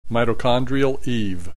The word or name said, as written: Mitochondrial Eve